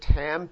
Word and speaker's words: tam: That's the word tam, T-A-M. It's pronounced Tam, and that word Tam means that he was a very special man and really quite righteous or quite a good man.